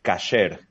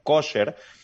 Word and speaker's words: kasher: Eh, lo que pasa es que, como ustedes sabrán, hay dos formas de pronunciar el hebreo, la forma sefaradí, que es de los judíos de España, eh, y también la forma de los judíos europeos, que la A la pronuncian O. Entonces se popularizó la fórmula ashkenazí y por eso todo el mundo lo conoce como kosher pero realmente lo que significa la palabra es cualquier cosa que esté lista, preparada, apta, se considera que es kosher.